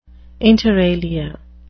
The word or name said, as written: inter alia